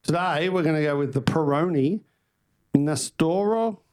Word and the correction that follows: Nastro Azzurro: How do you say? Nastro Azzurro, which is Italian for yogurt.